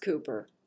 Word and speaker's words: Cowper: His name is spelled to look like Cowper, but it's pronounced Cooper.